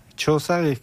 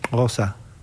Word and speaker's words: Xhosa: En Xhosa, Choza sería X-H-O-S-A, pero ellos lo pronuncian Osa.